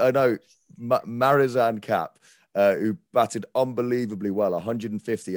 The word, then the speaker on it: Marizanne Kapp: But it was now I never say her name correctly, but it's Marizanne Kapp.